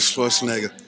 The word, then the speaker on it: Schwarzenegger: Arnold Schwarzenegger.